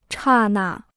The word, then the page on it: 刹那: chà nà